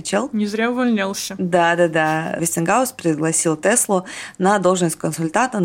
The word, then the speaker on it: Вестингаус: Вестингаус, по-английски там Вестингаус, причем хаус пишется прям как дом, а по-русски это Вестингаус, выкупил у Теслы более 40 патентов и заплатил в среднем по 25 тысяч долларов за каждый.